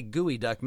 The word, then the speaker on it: geoduck: And when you say gooey duck, many people may not have heard of a gooey duck. It's not spelled like it sounds. It's actually spelled geoduck, but it's pronounced gooey duck.